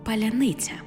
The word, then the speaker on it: паляниця: Погляньмо на фонетичну транскрипцію. Паляниця. Бачимо, що тверді й м'які, а в окремих випадках й пом'якшені приголосні ніби чергуються. Так само, як і в словах «залізниця», «годівниця», «робітниця». Таке чергування є легким для вимови тих, хто послуговується українською, проте непритаманне російській мові. Також у російській мові звук «ц» завжди твердий.